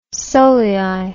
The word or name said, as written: solei